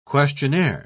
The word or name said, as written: questionnaire